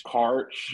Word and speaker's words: Quaritch: Uh, Stephen Lang uh, returns as how do you pronounce Quaritch? Quart. I think it's Quart. Quartch. – Quaritch. Yeah, none of these names.